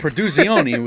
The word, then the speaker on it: Produzioni: But Produzioni was a good pronunciation.